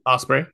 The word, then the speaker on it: Ospreay: Ospreay, how do you say his last name? – Ospreay, yeah.